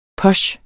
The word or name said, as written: POCHEN